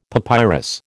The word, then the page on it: papyrus: American English